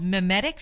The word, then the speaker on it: mimetic: It's called mimetic theory. Is that how yes.